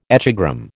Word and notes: echogram: American English

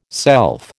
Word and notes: self: American English

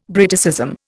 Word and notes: Briticism: Indian

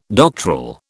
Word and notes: doctoral: British English

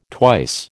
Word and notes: twice: American English

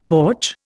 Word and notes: porch: Indian

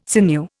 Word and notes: sinew: Indian

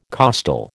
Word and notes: costal: American English

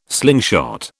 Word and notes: slingshot: British English

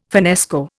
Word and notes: finnesko: Indian

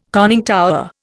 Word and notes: conning tower: Indian

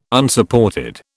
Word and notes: Unsupported: British English

